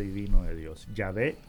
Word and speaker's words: Yahvé: Pues todos los hebraístas modernos están de acuerdo en que la manera original y primitiva de pronunciar el nombre de Dios debía haber sido Yahvé y no Jehová.